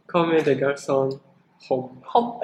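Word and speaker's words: Comme des Garçons Homme: How do you say that? – Come de garçon, home. – Are you sure that's Japanese or French? – That's French. – It's a French Japanese brand.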